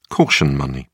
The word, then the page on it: caution money: British English